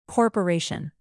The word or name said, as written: corporation